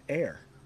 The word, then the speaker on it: heir: But air and air. I know there's an H there. It's a silent H. Forget about it.